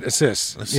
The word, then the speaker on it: Asus: Yeah, Asus, and you pronounce it Asus, you know, which is, you know, Pegasus.